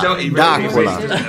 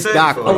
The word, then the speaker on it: D'Aquila: D'Aquila. – You really D'Aquila. – D'Aquila. It's D'Aquila. – Oh, almost like Dracula.